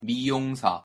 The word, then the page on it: 미용사: Korean